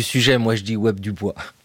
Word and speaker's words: Du Bois: Son nom s'écrit Du Bois, ça se prononce Du Bois.